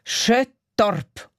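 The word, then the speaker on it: Skottorp: Sabine Brun, sie hat geschrieben und in Schweden ist das Halleluja aus dem Entlebuch auch richtig schön angekommen und man spreche den Ort in Schweden also Schöttorp aus.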